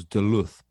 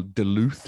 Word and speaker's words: Duluth: Or yeah, and, and on that one, I've always been corrected on the pronunciation because I, I, on reading it, thought it was Duluth, but I gather from Americans, oh, no. Duluth. – Duluth.